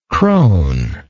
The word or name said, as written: cronish